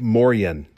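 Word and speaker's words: Moien: They say, Morgan or Morian, spelled M-O-R-J-E-N, and he says, parentheses, morning, all day long, or all day. It is pronounced something like Morian, so hopefully I did that right.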